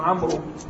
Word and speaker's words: عمرو: عمرو، غلط هذا، هذا غلط، هذا ليس، هي تكتب في خلفه واو صحيح، لكن هذه الواو لا تنطق،